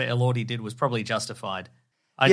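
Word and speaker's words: Elordi: Oh, Lordy Elordy, the Jacob Elordy incident.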